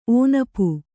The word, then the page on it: ಒನಪು: onapu